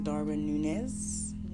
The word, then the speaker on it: Darwin Núñez: Because people had high hopes for Mr. Darwin Núñez, as it's pronounced.